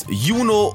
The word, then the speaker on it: Juni: Platz, Juno oder Juli. – Ja, alte Aussprache Juni und Juli, um Verwechslungen zu vermeiden, hat meine Oma auch immer früher gesagt.